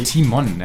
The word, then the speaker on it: Timon: Und zwar bei Pumba würde ich natürlich Timo sagen. Mhm. Ja, und oh, Timon, ja, das war falsch aus, war so ein bisschen ja, slangmäßig ausgesprochen.